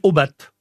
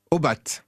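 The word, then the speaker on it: Aubat: Et alors, on va dire Obat. – Obat. – Voilà, on va prononcer le T terminal.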